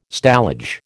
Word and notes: stallage: American English

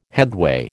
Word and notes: headway: American English

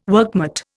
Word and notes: workmate: Indian

